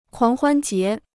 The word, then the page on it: 狂欢节: kuáng huān jié